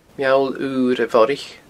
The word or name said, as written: Meall Ura Bhorraich